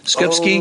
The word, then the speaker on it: Skupski: Skips Skupski.